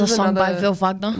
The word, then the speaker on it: Wagner: He pronounces his own name as Wagner, even though every time I mention his name, my mum's like, it's actually Wagner. I'm like, Mum, I know. But he just chooses to mispronounce it.